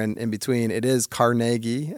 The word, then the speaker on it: Carnegie: You don't go to, but it is Carnegie. That is how Andrew Carnegie would have pronounced his name.